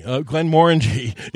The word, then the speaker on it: Glenmorangie: Glenmorangie is the correct pronunciation. It's really the only word in the English language that I've heard of that actually rhymes with orange.